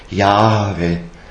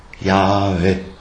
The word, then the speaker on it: Jahwe: Wird geschrieben als Tetragramm, also mit vier Buchstaben, J, H, W, H, interessanterweise, wobei das H wird als leichtes E gesprochen, Jahwe, Jahwe, also das, im H ist der strömende Atem drinnen, das so ein bisschen nach E vokalisiert wird.